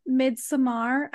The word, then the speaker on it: Midsommar: in Midsommar. I always feel like such a snob saying it that way, but that's how it's supposed to be pronounced.